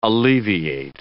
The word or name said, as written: alleviate